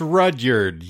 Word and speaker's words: Rudyard: Rudyard Kipling's The Jungle Book, H.G. Wells' Rudyard? – Rudyard? I don't know. – It's Rudyard, yeah.